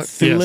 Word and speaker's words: Thewlis: Thewlis, is that how you say his name? – Is it uh, Thewlis yes.